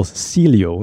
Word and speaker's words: Cielo: So this is from El Cielo.